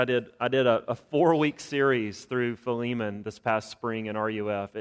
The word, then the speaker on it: Philemon: It's a little letter to Philemon. That's how I say it. I know there's lots of different pronunciations out there, but in South Alabama, he's Philemon. So we're going to stick with that this morning.